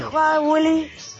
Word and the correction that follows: Halloween: ¿Cómo se dice? Halloween. Feliz Halloween, grupo.